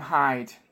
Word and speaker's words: Hyde: By the way, I just want to give my name is Sam Hyde. – Okay. – Thank you. – Spell that second name. – Sam Hyde, like Mr. Hyde. – H-Y-D-E.